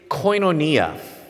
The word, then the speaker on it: koinonia: The word here is koinonia. Have you heard of that? Koinonia, or koinonia, as people differently pronounce this, it means fellowship.